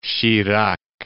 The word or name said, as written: Chirac